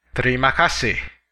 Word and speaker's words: terima kasih: In Indonesian, thank you is terima kasih. Terima kasih. Let's break it down by syllable.